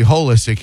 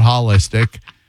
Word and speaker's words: holistic: I would ask that you pronounce it correctly, holistic hearing, not holistic, firstly.